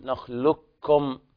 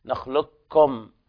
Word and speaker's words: نخلقكم: أيضا في قوله تعالى ألم نخلقكم من ماء مهين في سورة المرسلات، وجوب الإدغام الكامل في قوله تعالى ألم نخلقكم، نخلقكم هنا يلاحظ نطق الكاف فقط بدون القاف، يعني نقول نخلقكم كما يقول البعض في الإيميل دوت كوم، كوم نفس الكاف هذه نخلقكم بهذه الطريقة، لام وكاف، إذن إدغام كامل